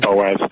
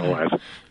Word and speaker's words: Boaz: Boaz. I know you want to say it like Boaz because of the OA, but it's Boaz.